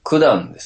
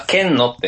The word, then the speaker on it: 件: 多分冒頭の、あれは、九段ですね。 – ん？ – あ、剣のって読みましたけど、あれ九段。